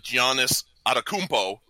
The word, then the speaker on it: Giannis Antetokounmpo: And but he's been making the announcements of Giannis Antetokounmpo, is how you say the name, not whatever he screamed at that one point.